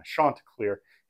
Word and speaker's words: Chanticleer: We are joined by a Coastal Carolina Chanticleer. He confirmed that I am saying that right, so I'm very excited to say it.